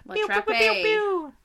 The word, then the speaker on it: La Trappe: La Trap. – La Trappe. La Trappe. – There's no accent on the E. It's just trap.